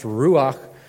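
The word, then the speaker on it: ruach (רוּחַ): Um, in Hebrew, it's, it's pronounced ruach.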